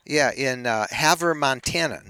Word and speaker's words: Havre: And in this case, the city we were picking was a city called Haver, and it's spelled H-A-V-R-E. Haver.